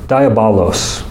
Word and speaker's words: Diabolos: By the way, the word used for slanderer in this passage is a Greek word, and it's I'm gonna need to make sure I pronounce it right. Diabolos.